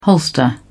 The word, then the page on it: holster: British English